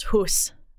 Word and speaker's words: house: H-O-U-S doesn't make any sense. It used to be pronounced hus.